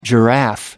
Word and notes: giraffe: American English

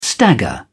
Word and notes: stagger: British English

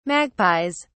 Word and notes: magpies: British English

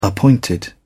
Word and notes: appointed: British English

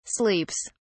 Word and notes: sleeps: British English

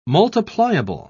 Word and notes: multipliable: British English

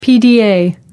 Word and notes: pda: American English

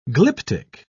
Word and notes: glyptic: British English

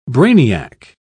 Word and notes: brainiac: British English

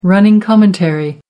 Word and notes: running commentary: American English

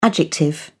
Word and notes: adjective: British English

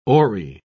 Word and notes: oary: British English

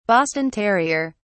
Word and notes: boston terrier: British English

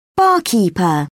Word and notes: barkeeper: British English

